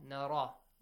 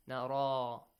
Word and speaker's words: نرى: Do you see after Ra we have a Ya? – Yes. – Which must be pronounced with like an alif. Nara.